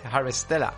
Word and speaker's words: Harvestella: Pues tengo por aquí Square Enix presenta Harvest Stella, o Harvest eh, coño, ¿cómo se dice? Harvestella, una mezcla de simulador de granja con RPG de acción.